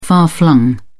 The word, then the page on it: far-flung: British English